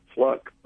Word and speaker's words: Fluke: Well, I do believe she pronounces it Fluck,